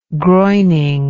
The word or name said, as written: groining